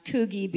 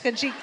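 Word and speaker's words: Coogee: A fisherman hooks a small shark off of what I'm imagining to be pronounced Coogie Beach. No? Yeah? – By the laughter, I'm going to guess no. – Coochie Beach? – Coochie.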